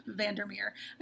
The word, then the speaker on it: Vandermeer: We'll see you again in 2 weeks where we're reading Annihilation by Jeff Vandermeer.